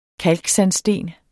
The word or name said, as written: kalksandsten